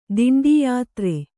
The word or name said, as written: ದಿಂಡೀಯಾತ್ರೆ